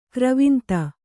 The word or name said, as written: ಗರ್ವಾಂಧ